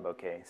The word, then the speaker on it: Bokeh: So Arbokeh, you can pronounce it Arbokeh as well, but the developers of Bokeh call it Bokeh, so that's what I call it.